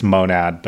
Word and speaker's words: Monad: So you're right that it's in the dictionary. It's monad, but we we always say monad.